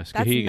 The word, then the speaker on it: Skowhegan: That's how you pronounce it, the first one. – Yeah, Skowhegan. That's not how I was pronouncing it in my head earlier.